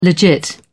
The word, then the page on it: legit: British English